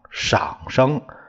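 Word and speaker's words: 上声: 上是这个念这个上下的上，这里念赏赏声，赏声是一个低平调，低声调，低声调。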